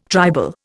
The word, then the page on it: tribal: Indian